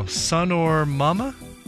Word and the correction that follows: Sonomama: How is that pronounced? Sonomama. There's no R.